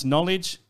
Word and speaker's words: knowledge: So when you pronounce knowledge, you know, you say the N, the K silent, you know what I mean?